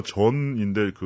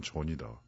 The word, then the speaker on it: 전: 그렇게 했었고 제가 전인데 그 여권에 JUN으로 쓰지만 이 J 발음이 JUN을 독일에서는 윤으로 발음해요. 그렇죠. 그러니까 제 성도 사실 다 지금 잃었죠. 음. 그래서 제가 여러 번 고쳐줬어요. 나는 윤이 아니고 난 전이다.